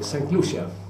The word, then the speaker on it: Sankt Lucia: Den heter Sankt Lucia. Men det stavas Sankta Lucia. Men det heter Sankt Lucia på originalspråk.